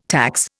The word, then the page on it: tax: Indian